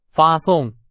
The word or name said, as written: 发送